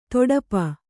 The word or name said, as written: ತುಡುಪು